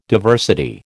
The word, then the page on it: diversity: American English